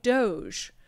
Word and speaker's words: DOGE: I'm saying that correctly, right? Doge. God, if I'm not, somebody would have told me by now. I literally went online and I typed in, how do you pronounce Doge? And I think that's it.